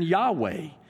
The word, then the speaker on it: Yahweh: The closest pronunciation of YHWH is probably what we see here in Yahweh.